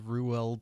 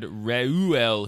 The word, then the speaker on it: Reuel: Yeah, the internet lady said Reuel Hebrew.